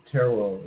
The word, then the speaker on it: tarot: The last T in tarot is silent.